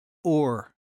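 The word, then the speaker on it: or (עור): The word skin in Hebrew is pronounced or. Did you get that? So in Hebrew, you pronounce skin or the same as light, except it's spelled a little bit different.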